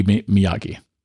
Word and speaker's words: Miyake: So anyway, yeah, the iPhone Pocket collaboration between Apple and Issei Miyake. I think that's how you pronounce their name. – It would be Miyake.